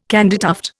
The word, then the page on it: candytuft: Indian